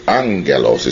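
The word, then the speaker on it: Angelos (ἄγγελος): Angelos is the proper pronunciation. It's spelt agelos, two G's coming together, G sounds coming together, is pronounced with an N sound.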